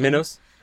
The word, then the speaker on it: Minos: Minos.